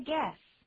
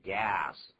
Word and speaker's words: guess: Take a guess. Take a guess. 也可以说成 guess 就好了。 – Guess 就可以了。但可不要念成 gas。 – 啊。 – 那个 gas 跟 guess 不一样啊。